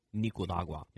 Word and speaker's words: Nicaragua: Nick Wallenda last night crossed Nicaragua. I can never pronounce that. I need your help. – Nicaragua.